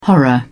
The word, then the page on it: horror: British English